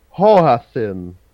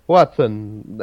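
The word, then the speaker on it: hoatzin: The question I have for you today is about the South American hoasin. Do you know that? It's a pheasant. And say it one more time. – South American hoasin. Hoasin. – Hoatzel? – Watson. Watson, that's how it's pronounced, obviously.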